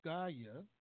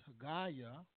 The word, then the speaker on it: Haggai: In the first chapter of Haggai, his name is truly pronounced Haggaiah, but the framers, the editors, took the name of Yahweh out of his name. But it's Haggaiah.